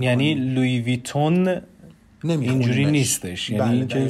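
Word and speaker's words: Louis Vuitton: لوی ویتون بر اساس آواها و فونتیک نزلیزه یا تو دماغی که در زبان فرانسه هستش لوی ویتون تلفظ میشه.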